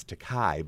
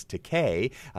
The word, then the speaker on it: Takei: And mispronounce his name is Takai, but it's Takei.